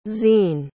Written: zene

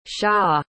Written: sha

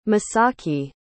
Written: masaaki

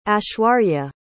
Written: aishwarya

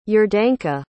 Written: iordanka

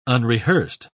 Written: unrehearsed

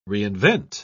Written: reinvent

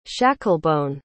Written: shacklebone

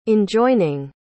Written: enjoining

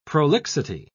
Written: prolixity

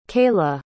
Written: keilah

